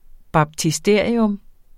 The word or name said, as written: baptisterium